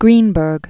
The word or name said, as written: Greenberg